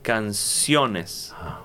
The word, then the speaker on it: canciones: and in plural, we change the stress. We are going to say canciones.